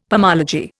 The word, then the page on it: pomology: Indian